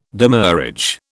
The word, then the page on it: demurrage: British English